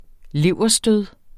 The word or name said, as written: leverstød